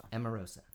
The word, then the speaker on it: Emarosa: Was Emarosa... correct pronunciation of the band. Let's do it. – Emarosa. Yes. – Emarosa. – Exactly.